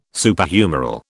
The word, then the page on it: Superhumeral: British English